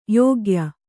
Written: ಯೋಗ್ಯೆ

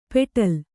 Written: ಪೆಟಲ

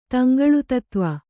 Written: ತಂಗಳುತತ್ವ

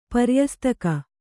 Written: ಪರ್ಯಸ್ತಿಕೆ

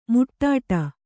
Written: ಮುಟ್ಟಾಟ

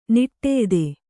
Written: ನಿಟ್ಟೆಯ್ದೆ

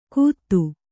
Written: ಗುತ್ತು

